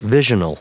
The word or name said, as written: visional